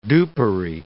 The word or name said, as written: dupery